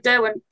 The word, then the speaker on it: Derwent: So when did you, or how did you come about being interested in, I want to make sure I'm pronouncing it right. It's Derwent, right? – Derwent? Yes, Derwent, yeah.